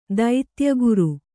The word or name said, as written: ದೈತ್ಯಗುರು